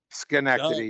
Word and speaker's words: Schenectady: Schenectady, yeah.